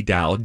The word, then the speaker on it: DAO: The group that tried to buy the Constitution formed a DAO, D-A-O, yes. pronounced like D-O-W.